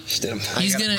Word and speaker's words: Stidham: I honestly think Stidham, if that's how you say his name. – Jared Stidham. – Stidham.